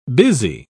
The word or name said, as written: busy